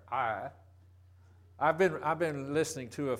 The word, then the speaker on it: Ai: He pronounces every one of these names different than I did. I've always said it just like Mo did, Ai, but he pronounces it I.